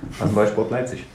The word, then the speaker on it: RasenBallsport Leipzig: Rasenballsport Leipzig.